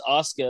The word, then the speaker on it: Asuka: It's Asuka.